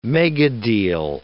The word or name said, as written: megadeal